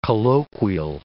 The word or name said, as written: colloquial